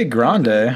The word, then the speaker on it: Grande: And is it Ariana Grande or Grande? – I would say Grande. I think it's pronounced Grande.